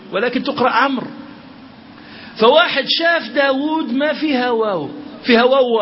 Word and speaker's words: داود: أن النبي صلى الله عليه وسلم قال بأن دعاء داود عليه الصلاة والسلام، توقفنا عن داود ولا لا؟